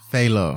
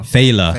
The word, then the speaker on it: Fela: I tried to get into some Fela, so I tweeted earlier. – Fela. – Is it Fela? – Fela. – Fela.